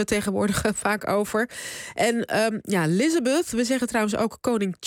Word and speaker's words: Elizabeth: In Nederland heeft ze gewoon altijd Elisabeth geheten, op zijn Nederlands uitgesproken en geschreven. Waarom zeggen jullie nu al dagen ineens Elizabeth? – Elizabeth, ja.